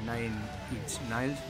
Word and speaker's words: Nine Inch Nails: Y vamos a poner a Nine Inch Nails, ¿no? No sé si lo he dicho bien. – Lo has dicho bien, sí.